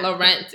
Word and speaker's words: Laurent: Laurent. It's Laurent. Real French with this name.